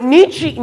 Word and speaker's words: Nietzsche: Fred, Friedrich Nietzsche, or Nietzsche, pronounced Nietzsche in German, famously wrote in his book, uh, Thus Spoke Zarathustra, or something like that.